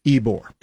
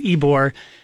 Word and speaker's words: Ybor: It's spelled Y-B-O-R, pronounced Ybore. – That's right, because in Spanish, Y makes an E sound, so right. It's Ybore.